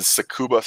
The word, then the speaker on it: Tsukuba: So the new track is going to be, and pardon me if I butcher this, which I probably will, is Tsukuba Circuit.